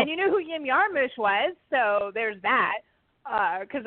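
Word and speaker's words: Jim Jarmusch: Everyone says Jim. It's Yim. It's Yim Yarmush.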